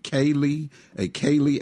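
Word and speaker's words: ceilidh: Is it Kaylee after party? – That's correct.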